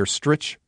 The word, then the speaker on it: Stritch: Stritch rhymes with itch.